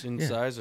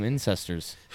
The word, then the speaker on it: incisors: Is that how you pronounce that? – What? – Incisors? – Incisors. – Yeah. Oh, I was calling them incestors.